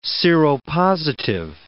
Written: seropositive

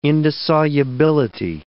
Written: indissolubility

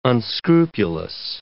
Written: unscrupulous

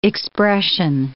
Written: expression

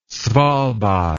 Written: svalbard

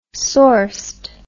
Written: sourced

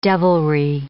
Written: devilry

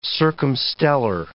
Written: circumstellar

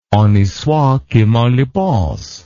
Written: honi soit qui mal y pense

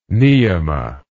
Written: niyama

